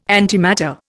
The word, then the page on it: antimatter: Indian